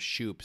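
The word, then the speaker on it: Schupp: And they got Jonathan Scope. I pronounced it correctly this time. – Thank you. Wow. – So they improved. – He actually changed the pronunciation to Shoop, so you're actually...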